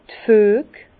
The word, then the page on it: d' Füeg: Bas-Rhin; Herrlisheim